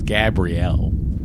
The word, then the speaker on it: Gabrielle: First of all, it's not Gabriel Beaumont. It's Gabrielle.